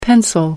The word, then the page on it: pencil: American English